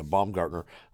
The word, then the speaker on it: Baumgartner: But played with him, Ryan Knoll, and we played with Brian Baumgartner. Is that, am I saying it right? Yeah, Baumgartner, or as you know him, Kevin from The Office.